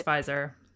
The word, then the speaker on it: Pfizer: It's Pfizer. – Okay, it's Pfizer.